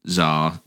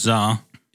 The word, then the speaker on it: Tsar: It's actually Tsar. – Tsar. – Yeah, pronounced Tsar. – even though it's not as got as TS, it's pronounced Tsar.